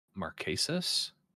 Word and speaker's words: Marquesas: I always mispronounce this season, but Marquesas? – You got it. You nailed it.